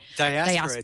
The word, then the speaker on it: Diaspora: Was it maybe 2 or 3 years ago now launched Diaspora, or was it Diaspora? Am I saying that right? – Diaspora.